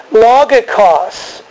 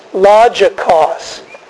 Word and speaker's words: logikos: The Greek word is logikos, sometimes pronounced logikos.